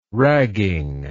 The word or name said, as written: ragging